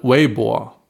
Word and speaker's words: Weibo: Jetzt zum Schluss widmen wir uns nochmal Weibo. Habe ich das richtig ausgesprochen? – Ja, du hast super richtig ausgesprochen.